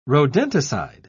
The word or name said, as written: rodenticide